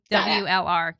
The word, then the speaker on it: CRAWLR: CRAWLR.